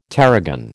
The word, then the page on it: tarragon: American English